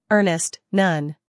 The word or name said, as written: earnest